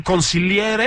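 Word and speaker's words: consigliere: Il conciliere. Ahí está la pronunciación en italiano, chingón, ¿no?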